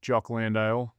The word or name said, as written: Jock Landale